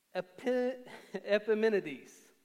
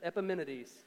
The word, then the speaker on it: Epimenides: Paul does that with Epimenides. And that's how you pronounce it. Don't question that. Epimenides.